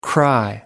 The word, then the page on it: cry: American English